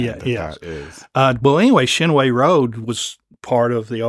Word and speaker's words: Chinoe: But growing up here in Lexington, we've always known it as Chinoe. – Chinoe. – Again, my school bus went up Chinoe, up to Christ the King. So I always have known it as Chinoe. It's Chinoe.